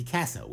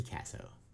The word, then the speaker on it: Kasso: So Ricky Kasso, which rhymes with asshole, as one of the kids in the interview said. – Is it Kasso? – Yeah, it's Ricky Kasso, because it rhymes with asshole. Ricky Kasso rhymes with asshole, and an asshole he was.